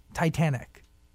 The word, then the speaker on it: Titanic: And how'd you meet Titanic? Did I say that correctly? – Yeah, like the ship.